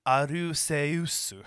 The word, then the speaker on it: Arceus: And yes, it is Arceus, not Arceus. I don't care what they decided, how they decided to pronounce it in the anime or anywhere else. Go back to the original Japanese. The name is Arceus. So, suck it. It's supposed to be pronounced Arceus.